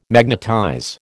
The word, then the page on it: magnetize: American English